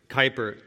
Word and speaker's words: Kuyper: Is it Kuyper or Cooper? – Kuyper.